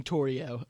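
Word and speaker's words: Torrio: But we see Torio, and I believe that's actually the pronunciation, Torio.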